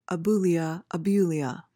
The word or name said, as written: abulia